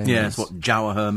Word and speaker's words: Jawaher: What's it called? – Jawaher. – How do you spell that? – J-A-W. – Yeah. – H-E-R. – Jawaher.